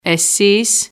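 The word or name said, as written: εσείς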